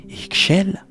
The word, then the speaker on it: Ixchel: Son nom, que l'on prononce Ixchel, signifie « femme au visage pâle », une allusion directe à la lumière lunaire qui éclaire doucement la nuit.